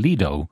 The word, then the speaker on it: Lido: That's Patrick Earls from the Dublin City, as he puts it, Lido campaign, but it comes from the Italian word for a coastline, Lido, and you think he might start there. That might be the first thing to do, to pronounce the word properly.